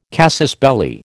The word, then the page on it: casus belli: American English